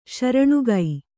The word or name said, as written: ಶ್ರೇಣಿಕೆ